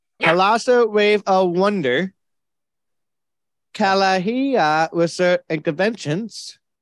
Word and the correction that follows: Kalahari: It's actually pronounced Kalahari Resorts and Conventions. – Kalahari. Thank you.